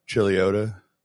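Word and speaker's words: Chuluota: He said there's an accident on Chiluda Road. I'm like, dude, you mean Chiliota? Because that's how it's pronounced. I mean, I know it's spelled weird, but it's Chiliota, not Choluda.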